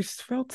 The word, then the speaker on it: svelte: Like, not sure if you know this word, but you're very svelte. Huh? – Yeah. I make sure you hear the S and the V right next to each other. – Svelte, just so we're clear.